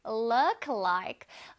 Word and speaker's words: look like: 입술이 팍 나와서 look like가 절대 아닙니다.